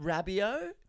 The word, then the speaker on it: Rabiot: It's pronounced Rabiot. It's not pronounced Pinot.